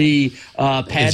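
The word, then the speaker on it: Eee Pad: Is it EEE or E? Like, how do you pronounce it? With the triple E pad transformer.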